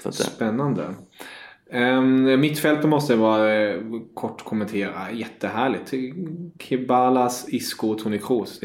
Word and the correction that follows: Ceballos: Ceballos där. – Ceballos, ja, förlåt.